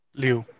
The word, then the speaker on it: Liu: And I don't know how to pronounce the actor's name. Simu Liu? – Liu. – Liu, okay. – Liu.